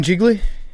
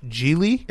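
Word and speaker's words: Gigli: Have you seen Jiggly? It's wonderful. – It's pronounced Geely.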